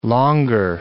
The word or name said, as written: longer